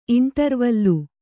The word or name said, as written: ಇಂಟರ್‍ವಲ್ಲು